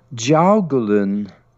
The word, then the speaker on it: Jiaogulan: It is called Jiaogulan herb.